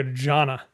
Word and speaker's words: Jeanne: The way it seemed to be pronounced in the movie was almost like a Jana. – Yeah. It's spelled Jean, or Jane, right. J-E-A-N-N-E. The problem is this character is married to a man named Jean, J-E-A-N.